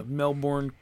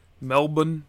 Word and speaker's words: Melbourne: Evolution's at the end of the month, and this is pretty cool that Australia got a big show at the Melbourne Cricket Ground. Even though some people say Melbourne, like bun. Have you been hearing that? – Yeah. Isn't it people from there say it like that sometimes? – They say Melbourne.